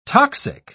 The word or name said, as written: toxic